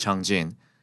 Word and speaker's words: Changjin: I just called it Changjin instead of Chosin because that is the real Korean pronounced name of the lake, which is currently a part of North Korean territory. The name Chosin is derived from Japanese pronunciation instead of the Korean pronunciation, and that was because the U.S.